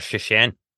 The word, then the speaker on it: Szczecin: Stettin or Szczecin. – Szczecin. So he was playing with Szczecin. – They're the same city. One's the German pronunciation and one's the Polish.